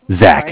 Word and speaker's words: Zach: Zach, sorry. Z-A-C-H.